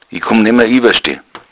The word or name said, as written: iwaschde